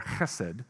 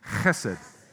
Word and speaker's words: chesed: And it's pronounced and written in English as both Chesed with an H, or Chesed is the more appropriate way to say it. Just so we're all clear, everybody say it with me, and use that little thing at the back of your throat to make the first sound, okay?